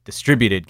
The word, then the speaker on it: distributed: Yeah, I mean, my earliest experience with like digitally distributed games, that sounded weird. Digitally distributed games. There we go. I put the emphasis in the wrong syllable.